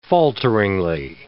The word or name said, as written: falteringly